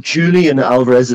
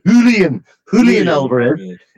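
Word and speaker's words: Julián Álvarez: Yeah, this is the 85th minute, and I've been told off many times by Colin Savage for pronouncing names wrong, but apparently it's not Julian Alvarez, it's Julian Alvarez.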